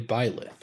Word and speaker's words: Byleth: I think in Japanese it's Byleth, but I think in English it's Blythe. – But I mean, maybe. So I thought it was Blythe, but the way it's spelled, it's definitely Byleth. – I think Sakurai says Byleth in the English voiceover.